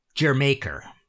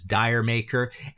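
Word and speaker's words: D'yer Mak'er: It's not really Jamaica. It's more like Jermaker, right? You know, and people have pronounced this Diremaker.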